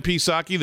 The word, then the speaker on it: Psaki: So here's Jen Psaki. The P is silent.